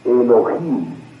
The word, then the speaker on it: Elohim: La H tiene sonido, se pronuncia como J, de manera que viene, su pronunciación exacta es Elohim.